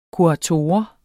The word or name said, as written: kurator